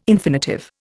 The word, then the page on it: infinitive: Indian